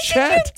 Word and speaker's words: Chet: And C-H-E-T, and he, your name is Oh, and, my God. – And I was like, no, Chet.